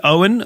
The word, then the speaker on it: Oen: So there's Owen, which is spelled O-E-N, which is short for the word Owenology.